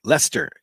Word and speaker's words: Leicester: Leicester is how it's pronounced.